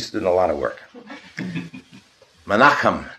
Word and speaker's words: Menachem: Am I mispronouncing that? Somebody, am I butchering that name? No, you can correct me. Correct me. – Menachem. – What? – Menachem. – Good. Thank you.